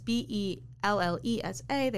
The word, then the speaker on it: Bellesa: So there are websites that focus, like, I know Belsa is one. It's either Belsa or Bellessa, and I'm very sorry if I'm saying that wrong. But it's Bellesa.